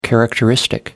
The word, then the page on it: characteristic: American English